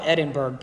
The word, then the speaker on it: Edinburgh: It's spelled Edinburgh, but they say Edinburgh.